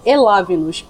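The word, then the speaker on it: Elavnos: Onde eles supostamente deveriam estar, por Elavamos Nós ou por Elavinos. O V é mudo. E-L-A-V-N-O-S. A gente tem Twitter, Facebook, Instagram e é isso.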